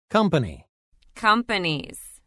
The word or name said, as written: Company – Companies